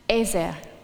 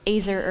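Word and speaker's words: Ezer (עזר): For all of you beautiful language nerds out there, the Hebrew word here is that one up on the screen, and it's pronounced Ezer, or Azer or Ezer is fine as well.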